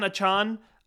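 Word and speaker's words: Achane: is Devon Achan. I think that's the new pronunciation, the correct pronunciation.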